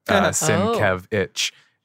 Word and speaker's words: Sienkiewicz: That was hard. Thank you for your website, Bill, because he actually pronounces it in his logo. Sienkiewicz. It's great.